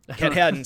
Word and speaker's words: Katahdin: Katahdin? – Katahdin.